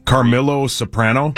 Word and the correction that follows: Carmela: Carmela Soprano. That's right. – Damn. Oh, how do you know that? Huh? – Very close, but you got to pronounce it correct.